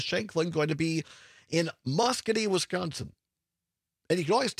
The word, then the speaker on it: Muscoda: Some people will say Muscoda, which is incorrect. – It's spelled like that. – It is not correct. It is Muscogee.